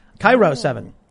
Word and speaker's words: KIRO: Florbo Adjacent says, Kiro 7 is pronounced like Cairo. Cairo 7.